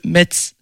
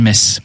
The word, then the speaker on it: Metz: Tu prononces mal, Metz. – Ah, Metz. – On dit pas Metz, on dit Metz.